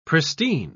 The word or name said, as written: pristine